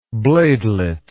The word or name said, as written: bladelet